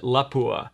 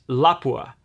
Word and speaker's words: Lapua: And down here in the U.S., especially in the Midwest, we tend to pronounce it Lapua. Apparently that's incorrect, and it's Lapua. It's not, there's no accent on the end.